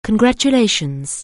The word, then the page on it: congratulations: anglais